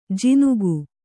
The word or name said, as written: ಜಿನುಗು